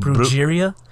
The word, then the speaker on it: Brujeria: You know, like, do Brujeria on? Is that how you say it? Brujeria? Brujeria? – Brujeria. Brujeria.